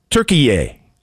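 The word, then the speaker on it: Türkiye: It's not Turkey anymore. It's Turkiye or Turkeye.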